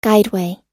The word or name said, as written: guideway